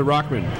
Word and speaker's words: Rockman: The Rockman. – Put your headphones on it and like... – Did I say it wrong? – No, it's a Rockman.